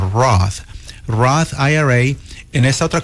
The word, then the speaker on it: Roth: Se llama Roth. – Roth, ajá, se escribe R-O-T-H.